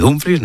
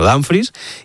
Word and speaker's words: Dumfries: Él mismo ha dicho que se dice así, se dice Dumfries, no Dumfries, y el club trabaja en cerrar la llegada de Diomandé y en iniciar las conversaciones con el Manchester City por Rodri.